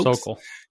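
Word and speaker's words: Sokal: You were instrumental in like the Sokol, I think I'm saying that right, Sokol hoax. – Sokol. – Yeah.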